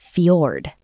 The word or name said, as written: fjord